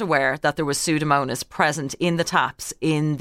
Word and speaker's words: Pseudomonas: it was a bacteria called Pseudomonas, I think. Am I pronouncing that correctly? – Yeah, Pseudomonas.